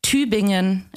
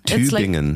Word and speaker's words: Tübingen: Tübingen. – Tübingen. – It's like T and then U with two dots. – Oh, yeah, yeah, yeah. – B-I-N-G-E-N. – Okay, yeah, that's it.